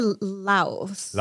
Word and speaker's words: Lao: A lot of people say, and I guess I should start the conversation by saying a lot of people say Laotian. I asked Kevin, the owner, how they prefer the topic or what is it? – How to say it. – Yeah. And he said they just say Laos. – Laos. – Yeah. So the Laos food or Lao food.